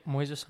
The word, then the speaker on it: Moises: Is it Moises? Is that how you say it? – Yep, Moises Henriquez.